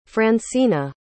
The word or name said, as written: francina